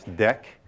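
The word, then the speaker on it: deque: And in fact, it's a double-ended queue, which we pronounce deck.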